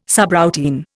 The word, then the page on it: subroutine: Indian